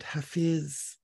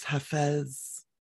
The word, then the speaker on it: Hafiz: It's a little excerpt from a Persian poet named Hafiz. Sometimes pronounced Hafez.